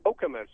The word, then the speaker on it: Okemos: It's actually pronounced Okemos.